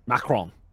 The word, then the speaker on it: Macron: It's spelled C-A-Y-N-E. There's also Private Korea. And Corporal Macron. Macron would be the appropriate way of saying it.